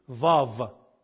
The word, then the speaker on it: Vav: W in Hebrew is called, that letter is called Wav. For W, the Hebrews use Wav. In Old Hebrew, it used to be Wav. In modern Hebrew, they pronounce it like V, Vav. But if you say it to the Hebrews, they know it both anyway.